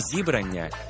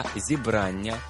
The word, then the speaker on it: зібрання: По-перше, не зібрання і не зібрання, а зібрання, правильний наголос.